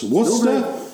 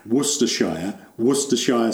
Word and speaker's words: Worcestershire: struggle with the pronunciation of Worcestershire sauce. It's like Oxfordshire.